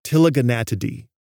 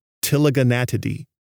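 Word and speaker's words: Ptiliogonatidae: The family most closely related to the waxwing family is Tilaganatidae. That's got a silent P at the beginning, Tilaganatidae.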